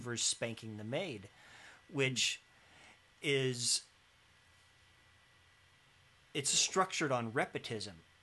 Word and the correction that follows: repetition: Did I say that right? Repetition. Repetition.